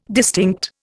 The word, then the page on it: distinct: Indian